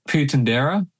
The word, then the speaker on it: Puentedura: How do you pronounce uh, his name? – Yeah, Puentedura. Puentedura. Okay.